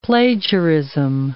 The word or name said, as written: plagiarism